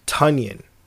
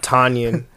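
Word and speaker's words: Tonyan: Apparently his name is pronounced Tunyon. – Like a ton. Like the weight. – Tunyon or like Funyon. – Ah, like a Funyon. Yeah. – Yeah. Not Tanyan, apparently, as we've been doing it.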